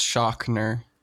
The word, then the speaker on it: Schochner: It's pronounced Schochner. – Schochner. – Yes. – All right, Carter Schochner. – Yeah, it's you got to get with your German IPA manual and and look it up.